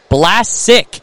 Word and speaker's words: Blasic: So Blasic, when you pronounce his name, you have to pronounce it like you're saying the word black. Blasic.